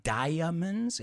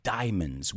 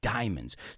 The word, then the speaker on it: diamonds: It's not diamonds, it's diamonds. We don't pronounce the A, diamonds.